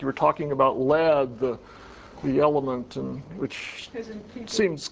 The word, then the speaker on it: lede: You start out with what we call the lead, which for obscure journalistic history reasons is spelled L-E-D-E.